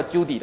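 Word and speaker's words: Cudi: 那这个红圈圈一点的这个就是亚拉拉山，那这个画了一点好像山山哈。哦，这里还有一个叫库迪达，这个是另外一个山，叫其实应该按他们的发音叫 Judy 的哈，我们英文这样叫库迪，其实 Judy 这个 Judy 山，这是阿拉拉山。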